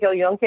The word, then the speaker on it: El Yunque: So, and I remember just being, I did the El Yunque, I think, is that how you pronounce it? El Yunque Rainforest? – Exactly.